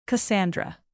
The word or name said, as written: cassandra